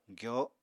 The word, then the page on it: gyoo: apatani